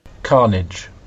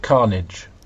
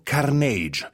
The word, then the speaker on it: Carnage: Si dice Carnage e noi invece abbiamo detto Carnage. Abbiamo sbagliato l'accento, l'abbiamo messo sulla I dopo perché facevano tutti così a Venezia.